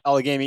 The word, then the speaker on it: Allegheny: We were hunting the Allegheny, I think that's, I'm not sure if that's how you pronounce it, forest. It reminded me. – Allegheny Forest. – Allegheny, yeah.